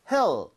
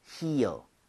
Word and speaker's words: heel: 얘는 모음이 이렇게 연결이 돼 있으면서 입 모양을 좀 벌려야죠, 그렇죠? – 네. – 그래서 얘는 그냥 heel 이렇게 하는 게 아니라 heel, heel.